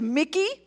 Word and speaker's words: Mackay: So one thing, they pronounce the Mackay House as Mickey. And the reason why they do that is because in the English transliteration from Hindi, that's how it sounds. It sounds like Mickey.